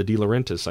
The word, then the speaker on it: De Laurentiis: The De Laurentiis, I think, I'm pronouncing it correctly.